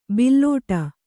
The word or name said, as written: ಬುಲೆಟ್ಟು